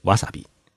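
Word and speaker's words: わさび: 芥末在日语当中念作是瓦萨比。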